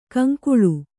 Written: ಕೌಂಕುಳ್

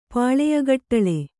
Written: ಪಾಳೆಯಗಟ್ಟಳೆ

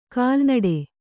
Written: ಕಲ್ನಡು

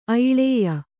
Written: ಐಳೇಯ

